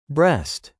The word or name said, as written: Breast